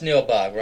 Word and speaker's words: Nilbog: Yeah, John, that's Neil Bogg, right. Spell it? Yeah. N-I-L-B-O-G.